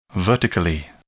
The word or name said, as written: vertically